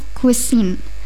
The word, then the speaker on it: cuisine: Jadi for the first word, I have cuisine. The pronunciation is C-U-I-S-I-N-E. Cuisine.